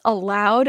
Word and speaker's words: Aloud: It's called That's Allowed, and it's spelled A-L-O-U-D.